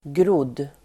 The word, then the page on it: grodd: [grod:]